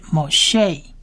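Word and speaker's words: Moshe (משה): 第四个字是 Moshe Moshe Moshe，大家听起来大概就知道是摩西的意思。 Moshe 有三个字母，第一个字母念 Mo，第二字母念 She，第三个字母是 H 的音，它不发音，所以这个字是 Moshe， Moshe 就是摩西的意思。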